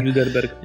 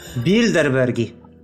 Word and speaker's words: Bilderberg: Bilderberg. – Bildenbergowie. Jak, jak to się wymawia? Bill. Bildenbergi. Bildenbergi. Po prostu mówisz szybko. Yy, po prostu znaleźli kolejny sposób, aby... Bilderbergi. Yy, a przez R.